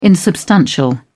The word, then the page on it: insubstantial: British English